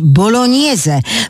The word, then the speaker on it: bolognese: W oryginale tę nazwę pisze się spaghetti bolognese, co powinno się wymawiać bolognese, bo zbitka głosek G i N daje ni.